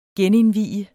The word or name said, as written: genindvie